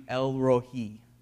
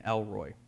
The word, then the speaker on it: Elrohi: It's actually Elrohi, which is a little bit more difficult to say than Elroy.